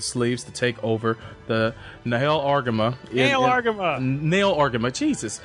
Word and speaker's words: Nahel Argama: How do you pronounce that again? The Nahel Argama and Garin Sierras.